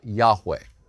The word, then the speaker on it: Yahweh: We think that that is probably properly pronounced Yahweh.